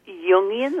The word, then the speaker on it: Jungian: We would say Jungian, like Jungian. – Jung. – Oh, uh-huh. Jungian. – Yeah, it's kind of a soft, loopy J. – Oh, sounds very Asian. Jungian.